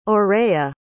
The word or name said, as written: orea